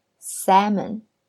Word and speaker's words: salmon: 注意呢，salmon 这个词它有一个 l，s a l m o n，但是这个 l 是不发音的哟，所以我们发音的时候是 salmon。